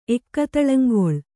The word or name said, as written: ಎಕ್ಕತಳಂಗೊಳ್